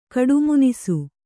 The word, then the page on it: ಕಡುಮುನಿಸು: kaḍumunisu